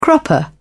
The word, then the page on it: cropper: British English